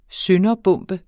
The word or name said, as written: sønderbombe